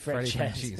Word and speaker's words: Francouz: He's better than Francouz, but how do you pronounce his name, actually? – Francouz. – Frankie Cheese. – It's actually, Freddie Francouz.